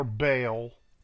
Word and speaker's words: Baal: Or Baal, if you can't pronounce it, but it's Baal.